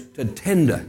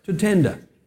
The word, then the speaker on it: Tatenda: And behind Peter is Tatenda. Now, if you can think of the word tender, think of a tender man and just double the T. Tatenda. And that's how you pronounce his name.